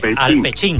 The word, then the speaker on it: alpechín: alpechín, creo que lo digo bien, alpechín.